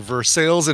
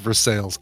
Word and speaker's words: Versailles: Merrick says, I'm from Pittsburgh and it's pronounced Versailles.